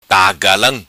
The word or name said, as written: Tagalam